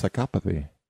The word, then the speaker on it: psychopathy: I've never heard it pronounced psychopathy. – Yeah, that's how it's pronounced.